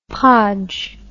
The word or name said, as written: podge